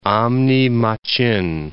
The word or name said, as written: Amne Machin